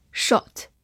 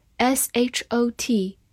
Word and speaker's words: short: short s h o t short 字母 o 发短音 o，short 或者美式发音口型比较大，shot 也是可以的。